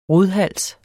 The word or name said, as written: rodhals